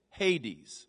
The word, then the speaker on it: Hades: But it was called Hades, H-A-D-E-S. It's literally, that's how it's pronounced in the Greek, Hades.